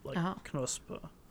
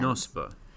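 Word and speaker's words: Knospe: The Wiktionary pronunciation does pronounce the K. Knospa. – That makes sense. – Knospa.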